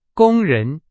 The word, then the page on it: 工人: Chinese